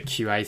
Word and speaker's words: Qi: Yeah, I mean, I think the big driver for it, we might as well talk about the QI thing because it is kind of ports. The QI wireless charging standard is it Qi? Sorry. – That's how you say it.